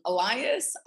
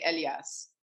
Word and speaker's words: Elias: Elias, I say it, but the Arabs say Elias.